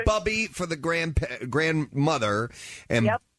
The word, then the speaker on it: Bubby: I just wanted to let you know that it's Bubby. It's pronounced Bubby.